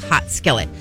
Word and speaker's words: Haute: It's spelled H-A-U-T-E. And I always said Haute Skillet. – Okay. – Haute Skillet, right? – Sure. – Like and that's just when we were talking about it. Everyone's like, it's not Haute Skillet. It's Hot Skillet.